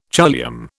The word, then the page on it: choliamb: British English